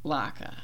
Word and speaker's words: Laika: Not Latka. that's like a Jewish cake, isn't it? It's like a potato pancake, isn't it? Okay, not Latka. Laka.